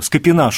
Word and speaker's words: Скопин: Ведь мы помним, что была возможность поддержать племянника Шуйского, Скопина Шуйского. Я, кстати, правильно произношу, он Скопин? – Да, да, да.